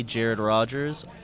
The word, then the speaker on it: Rogers: You can find me on almost all social media platforms at the Jared Rogers, R-O-G-E-R-S. R-O-G-E-R-S is how you spell it.